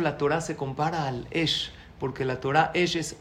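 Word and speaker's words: Esh (אש): Se dice Esh. Esh se escribe Aleph Shin. Aleph es porque la Torah te hace grande, te hace un Ish, te hace una Isha.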